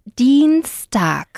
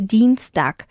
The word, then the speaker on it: Dienstag: Eh, martes es Dienstag. – Dienstag. – Muy bien. Con Una A bien, bien pronunciada, tag, ¿vale? Dienstag.